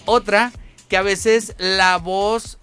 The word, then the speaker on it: Bose: Porque luego dicen Bose, pero es voz.